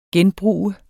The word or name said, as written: genbrug